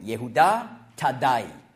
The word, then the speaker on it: Jehuda Thaddai: Also sein voller Name ist auf Hebräisch ausgesprochen Jehuda Thaddai. Thaddai ist das gleiche wie Thaddäus, griechische Aussprache.